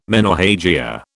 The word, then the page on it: menorrhagia: British English